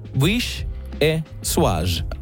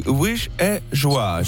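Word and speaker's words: Vichyssoise: Hei, Vicious se Dane sivulla on pronounced wish e suage. Wish e suage.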